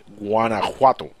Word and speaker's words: Guanajuato: Guanajuato.